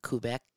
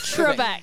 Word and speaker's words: Trebek: I mean, I went on Alex Kubek show. – Alex who? – How do you say it? – Quebec is in Canada. – Quebec, Canada. Alex, I can't say it. – Trebek.